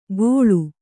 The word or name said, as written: ಗೋಳು